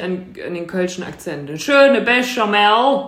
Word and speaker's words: béchamel: How do you go about pronouncing it in French? Because this is certainly a French word. This would be pronounced béchamel.